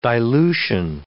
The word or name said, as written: dilution